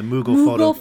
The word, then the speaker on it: Moogle: Right, I have someone over here on the streams talking about the, how do you say it that again, Moogle? – Yeah, the Moogle photo missions.